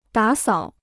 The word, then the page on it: 打扫: dǎ sǎo